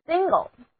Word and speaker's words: single: Single，字母 n 的读音是长的后鼻音 n，而字母 i 要读作短的 a。